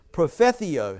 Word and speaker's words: propheteuo: The word prophecy in the Greek is prophetheo. And I'll spell that to you and so that you can see that I'm pronouncing it correctly. P-R-O-P-H-E-T-E-U-O. Okay, did I pronounce it right?